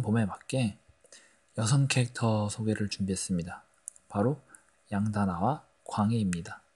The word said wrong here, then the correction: 강외: 강외 네, 발음이 좀 샜네요.